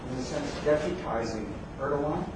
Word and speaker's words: Erdogan: So this is, again, a silent G, that's why it's Erdogan.